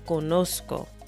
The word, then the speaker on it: conozco: And that's spelled C-O-N O-Z-C-O, o en español, C-O-N-O-Z-C-O. And it's important to recognize that the Z in Spanish is not pronounced like the Z in English. It's pronounced a lot more like the letter S. Entonces, it's not yo conozco. Es yo conozco.